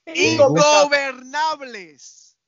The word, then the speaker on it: Ingobernables: Incorpenables. – No. – You're still saying it wrong. – Los Incorpenables. – Incorpenables. – No. – Ingobernables. – ¿Te gusta la what? – Ungovernables.